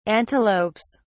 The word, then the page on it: antelopes: British English